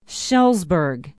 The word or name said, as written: shullsburg